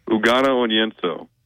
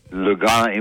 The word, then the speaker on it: Ugonna Onyenso: How do you pronounce his name? – Ugonna Onyenso. – Go ahead, Dad. Try to say that. – Lugano, you info? No, you're not even close.